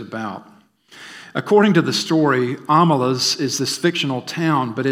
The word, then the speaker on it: Omelas: Amelas is a place name. It's spelled O-M-E-L-A-S.